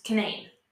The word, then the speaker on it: Canaan: And then we have Canaan, which in the Bible is canon, because it's spelled the same. – Right. – But here in West Virginia, it's Canaan.